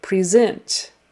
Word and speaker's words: present: It is spelled the same way, but you say it present. So you change the pre to a pre and put the accent on the second syllable.